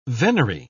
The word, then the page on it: venery: British English